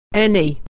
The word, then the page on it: any: e